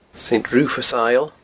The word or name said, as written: Saint Rufus's Isle